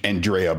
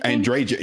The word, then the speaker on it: Andreja: My favorite, and I always screw her name up, but I would say Andrea, but that's not how you say it. How do you say it? – Andreja.